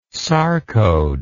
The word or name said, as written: sarcode